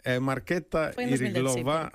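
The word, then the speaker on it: Markéta Irglová: Marqueta Irglova, gañadores.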